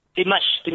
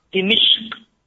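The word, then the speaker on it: Dimashq (دمشق): Membacanya dalam bahasa Arab adalah Dimashq. Dalnya di kasrah, mimnya di fathah, Dimashq. Demikian cara baca yang terkenal. Nah, ada juga ulama yang mengatakan cara bacanya mimnya di kasrah, Dimashq.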